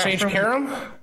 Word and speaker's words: Sage Karam: He's not Sage Karam? – Yes.